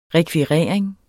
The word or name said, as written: rekvirering